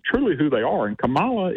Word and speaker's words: Kamala: I think it's Kamala. I think that I think the emphasis is on the first. So it's Kamala.